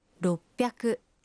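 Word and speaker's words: Роппяку (ろっぴゃく): 600… «Роппяку».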